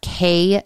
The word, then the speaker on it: Quay: Next are Quay sunglasses, and that's spelled Q U A Y.